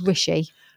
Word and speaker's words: Rishi: Richie Sunak. Have I pronounced him right? Richie Sunak. – I think it's Rishi.